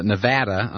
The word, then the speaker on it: Nevada: Nevada, I'm sorry. I pronounce it like Bush, and I shouldn't.